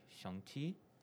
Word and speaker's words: Shang-Chi: So I have a lot of friends who have Chinese backgrounds and they say that like the proper pronunciation is more like Shang-Chi.